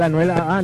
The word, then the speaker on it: Anuel AA: No, literal, Anuel AA, literal así.